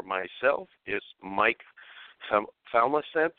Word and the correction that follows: Familant: So I'd like to bring Mike on and let's hey, Mike, how do you say your last name? I'm pretty sure I butchered it. – Yeah, you did. it's Familant. – What's that? – Familant. – Familant, okay.